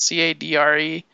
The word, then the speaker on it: cadre: the Cadre. How do you pronounce that? Is that how you pronounce it? Cadre? – I think so.